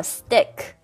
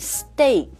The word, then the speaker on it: steak: steak or stick or stick. Yeah, stick comes up too. – 对，因为它的拼法是 S T E A K，对吧？但其实它的读音是 A steak.